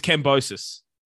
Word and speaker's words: Kambosos: It rhymes with ferocious. That's the whole point. – So it is Kambosos.